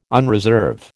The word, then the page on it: unreserve: American English